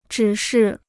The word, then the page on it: 指示: zhǐ shì